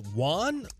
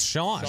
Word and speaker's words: Xuan: X-U-A-N, last name Tai, literally Tai. She is an investigative and enterprise reporter for ESPN. Is it Juan? – Isn't X usually Schwan? – Schwan? – Maybe it's like my name pronunciation. – Yeah, it's Sean.